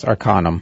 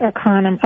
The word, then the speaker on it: Arcanum: Is it Arcanum? – Yes, Arcanum. – Is that how you say it? – Yeah. – Arcanum, okay.